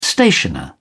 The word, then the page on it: stationer: British English